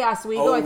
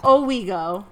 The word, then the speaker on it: Owego: I keep wanting to say Oswego. I think there must be an Oswego, Illinois. I'm from Illinois, so I keep like being like I'm saying it wrong. So Owego.